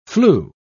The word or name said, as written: flu